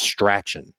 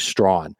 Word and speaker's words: Strachan: His name is Michael Strawn, but it looks like his last name is pronounced Strachan. But it's actually Strawn.